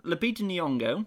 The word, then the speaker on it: Lupita Nyong'o: Well, the other ones, you have Lupita Nyong'o.